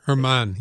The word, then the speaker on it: Germán: Is it Domingo Germán? Is that how you say it? – Germán, yeah.